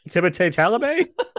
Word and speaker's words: Timothée Chalamet: featuring Taylor Russell and Timothée Chalamet. I think I said that right. – Let's say it that way. – Timothée Chalamet? – Yeah, I guess that's right.